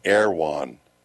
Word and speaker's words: Erewhon: It's spelled Erewhon. So we were trying to figure out what the heck is that. They pronounce it Erewhon.